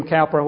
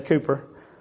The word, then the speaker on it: Cowper: William Cooper, or William Cowper, whatever you want to pronounce it. British say Cooper. And he's British, so I guess they can get away with that.